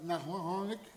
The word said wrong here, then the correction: Noahic: covenant, Noahic covenant.